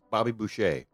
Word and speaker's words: Boucher: Entering the loading area, going past Chef, and I cannot pronounce this properly because it kind of looks like douchey, but it's with a B. – Bushy. – Bushy. But I can't believe that's right. – It's Bobby Boucher.